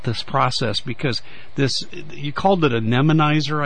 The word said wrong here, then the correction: memonizer: I believe it was, and excuse me if I'm mispronouncing some of these things. – It's a memonizer. It's memonizer, okay. – M-E-M-O-N-I-Z-E-R.